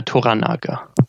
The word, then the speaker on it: Toranaga: Toranaga.